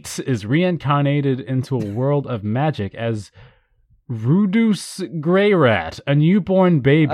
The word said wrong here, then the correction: Rudeus: I think it's pronounced Rudeus.